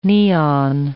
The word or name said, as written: neon